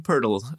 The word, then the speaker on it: Poeltl: Yeah, Jakob Poeltl is how I would pronounce it. – Thank you. Poeltl. – There's a non-existent R in there that you have to pronounce.